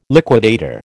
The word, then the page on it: liquidator: American English